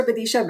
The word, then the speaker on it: Bidisha: Uh, so, I'm Professor Bidisha Biswas.